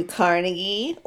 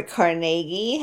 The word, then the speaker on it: Carnegie: Have you ever heard of Andrew Carnegie or Carnegie, however you want to pronounce it? – I've heard of Andrew Carnegie, yes.